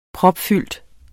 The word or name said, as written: propfyldt